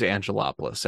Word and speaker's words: Angelopoulos: But if you want to find me on LinkedIn, it's James Angelopoulos.